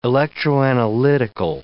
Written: electroanalytical

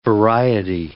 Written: variety

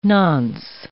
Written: nonce

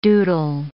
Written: doodle

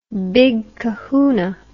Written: big kahoona

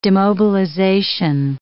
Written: demobilization

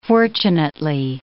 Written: fortunately

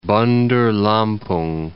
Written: Bandar Lampung

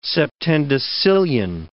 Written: septendecillion

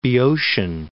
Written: Boeotian

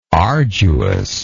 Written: arduousness

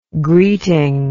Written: greeting

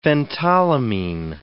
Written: phentolamine

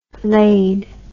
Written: layed

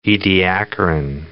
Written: Ediacaran

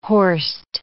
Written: horst

